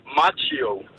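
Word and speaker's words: Macchio: Så ham der, Ralph Karate Kid, hvordan udtaler man navnet? – Macho. – Så det er macho, ligesom at være macho? – Ja, og, macho.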